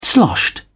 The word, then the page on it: sloshed: British English